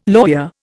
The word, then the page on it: lawyer: Indian